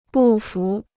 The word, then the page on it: 不服: bù fú